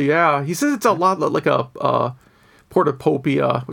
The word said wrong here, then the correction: Portopia: which I mispronounced, or Shadowgate, point and click. Those are actually pretty different games. I tried to do the Portopia mystery game, just because I know it's like a hugely influential game in the genre.